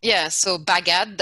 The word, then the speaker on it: Bagad: How do you pronounce that, if you don't mind? – Oh, so Bagad, that's the name.